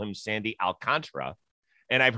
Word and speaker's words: Alcantara: And the pronunciation key on Baseball Reference is Alcantara. But I agree, people say it differently all the time.